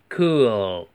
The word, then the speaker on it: cool: Cool，这里注意的是那个 l 的发音哈，边音 cool，把舌尖顶在上齿的根部，气流从嘴角出，cool。